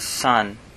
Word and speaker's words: saan: Phrase 2 is saan ka pupunta? Saan ka pupunta? Saan ka pupunta? This means where are you going? Colloquially speaking, saan gets pronounced as san, just one syllable.